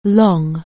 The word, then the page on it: long: anglais